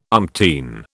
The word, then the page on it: umpteen: British English